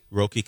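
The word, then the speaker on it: Roki: Roky Sasaki.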